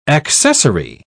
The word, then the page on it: accessary: British English